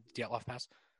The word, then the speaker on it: Dyatlov: I'm sure that there are people in the world who have heard of the Dyatlov Pass, right? Is that how you pronounce it, right? Yeah, Dyatlov Pass.